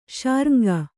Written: ಶಾರ್ಙ್ಗ

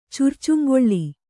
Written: ಚುರ್ಚುಂಗೊಳ್ಳಿ

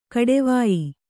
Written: ಕೊಡುವಾಯಿ

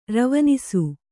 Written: ರವನಿಸು

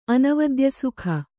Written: ಅನವದ್ಯಸುಖ

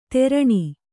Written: ತೆರಣಿ

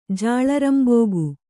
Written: ಜಾಳರಂಬೋಗು

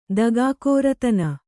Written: ದಗಾಕೋರತನ

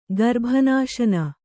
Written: ಗರ್ಭನಾಶನ

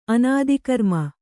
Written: ಅನಾದಿಕರ್ಮ

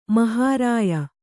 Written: ಮಹಾರಾಯ

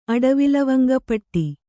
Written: ಅಡವಿಲವಂಗಪಟ್ಟಿ

